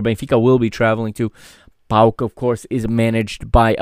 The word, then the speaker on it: PAOK: Okay, it's not PAOK, PAOK. Okay, Portuguese people are not always very good at pronouncing club names from difficult languages. It is pronounced PAOK.